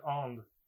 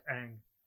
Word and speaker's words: Aang: And name is Ong, not Aang. – Yeah, like, the reason for that being sort of like, it's an Asian name. These are Asian names. They should be pronounced accurately.